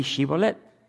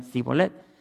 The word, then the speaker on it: Shibolet: Decían no, pues di Shibolet. Y no podían pronunciar la SH. Shh. Decían, sí, no pueden decir Shibolet. Nomás decían Sibolet.